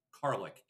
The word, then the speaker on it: Karlik: Sorry, I mispronounced it on our way out of the last break. It's Karlik, K-A-R-L-I-K, kind of like garlic, I guess.